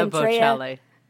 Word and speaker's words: Bocelli: And then Andrew, how do you say his name? Andrea, Andre Buccelli. – Andrea, Andrea Bocelli.